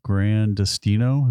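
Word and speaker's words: Gran Destino: At Grand Destino.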